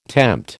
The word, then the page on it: tempt: American English